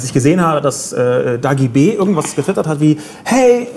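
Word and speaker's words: Dagi Bee: Dagi B. heißt sie. Dagi B. Like a bee, wie eine Biene.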